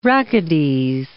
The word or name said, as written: rachides